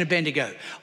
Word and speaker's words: Abednego: Look at Daniel, Shadrach, Meshach, and Abednego. I always remember how to say the name: shake the bed, make the bed, and in the bed we go.